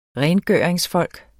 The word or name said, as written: rengøringsfolk